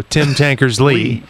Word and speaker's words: Tankersley: I thought that's the emphasis is on the last syllable. – Oh, Tim Tankersley.